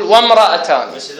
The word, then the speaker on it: وامرأتان: وام قبل من الواو تروح وين؟ للميم. وامرأتان ما نقول و و و ما تطلع، ما تطلع بالنطق، و ما تطلع عندك، لازم تقول وامرأتان.